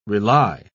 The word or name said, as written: rely